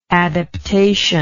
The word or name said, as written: adaptationally